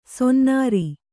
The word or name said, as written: ಸೊನ್ನಾರ